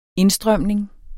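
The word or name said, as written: indstrømningen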